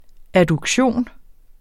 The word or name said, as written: adduktion